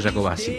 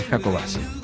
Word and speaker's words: Jacobacci: Ah, una de las cosas que me sirvió este programa es saber que no se dice Ingeniero Jacobacci, es Jacobacci.